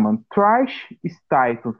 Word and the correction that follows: Stratus: Como que se pronuncia, Johnny? – Que? – A Trish lá, mano. A tri... Trish, mano. – Trish Stratus. Stratus.